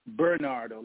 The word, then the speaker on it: Bernardo: It's Bernardo, like Bernard with a O, Bernardo Kestrup.